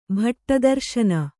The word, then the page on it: ಭಟ್ಟದರ್ಶನ: bhaṭṭa darśana; Kannada